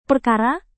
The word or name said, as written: perkara